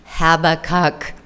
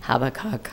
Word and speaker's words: Habakkuk: Habakkuk. Like, you can look them up on Facebook, YouTube, and they pronounce them for you. Habakkuk, Habakkuk.